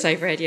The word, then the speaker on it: Seyfried: Is this Seyfried? – It is Seyfried, yeah.